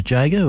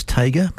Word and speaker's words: Tjader: Cal Tjader, T-J-A-D-E-R. I believe it's pronounced Jager, or some people tell me it's Tager, but yeah, you have a choice.